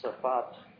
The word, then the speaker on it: Safat: And someone like Matthew Safat, that's the way his name is pronounced, a Jewish scholar, says it's tantamount to a refutation of those arguments.